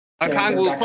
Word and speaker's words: Okongwu: You know, John Collins would have been a big Nate McMillan guy in that offense, along with Clint Capella and Okongwu. Is that how you say it? – Okongwu, close enough.